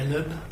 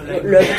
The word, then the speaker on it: Loeb: Donc, La Loeb. – Voilà, Loeb, tout simplement, la Loeb en fait.